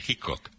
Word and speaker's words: Hickok: Sí, sí, luego, pues además él pronuncia como hemos pronunciado aquí a lo largo de la noche, Hickok.